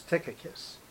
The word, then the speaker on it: Tychicus: So it's Tychicus.